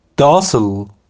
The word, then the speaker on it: docile: Docile, docile, docile. یه خورده دیکتش با تلفظش فرق داره. Docile, D-O-C-I-L-E.